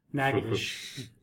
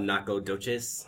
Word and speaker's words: Natchitoches: He's from Natchitoches, and I just want to show off that I know how to pronounce Natchitoches. That was my main point of bringing that up, was that he's from Natchitoches. – You mean it's not Nacogdoches? – No, apparently it's Natchitoches.